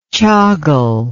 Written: chagul

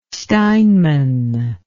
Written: steinman

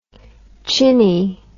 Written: chinny

